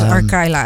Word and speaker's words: Arkayla: We spellen dit Arkayla. – Nou, dat zou ik ook als Arkayla uitspreken, ja.